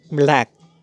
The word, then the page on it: Black: /blæk/